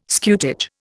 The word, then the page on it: scutage: Indian